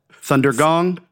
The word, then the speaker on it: Thundergong: Thunder gong.